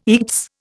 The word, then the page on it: eats: Indian